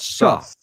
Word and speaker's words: Suh: Suh. That sounds like something Old Dirty Bastard would say on a track. – Well, it's spelled like Indomicon Sue. It's spelled the same, but he pronounces it Suh.